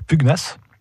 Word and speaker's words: pugnace: On peut citer encore pugnace, et on prononce gno d'ailleurs, pugnace et euh, je dirais en dernier vorace.